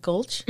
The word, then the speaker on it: Gulch: But the scene where Miss Gulch, am I saying that right? Gulch? – Mm-hmm.